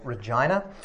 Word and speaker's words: Regina: I wanted to turn my back on the church, and so I went to university in my local city called Regina. Apologize for the pronunciation. That is how it is pronounced.